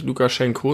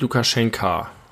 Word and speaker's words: Lukaschenka: Ja, aber du hast korrekterweise unsere Folge nicht Lukaschenko, sondern Lukaschenka genannt. – Lukaschenko ja. ist, glaube ich, die russische Aussprache oder so. Ja, er heißt also eigentlich Lukaschenka.